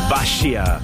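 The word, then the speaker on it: Basia: Basha. Así se pronuncia su nombre y con esta canción pegó mucho.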